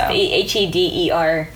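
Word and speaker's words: Heder: And that is John, I think it's pronounced Heater. – Think so. – Heeder.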